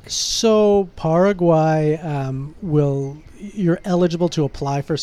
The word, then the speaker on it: Paraguay: Paraguay, how do you say it? – Paraguay, yeah.